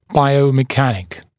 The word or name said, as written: biomechanic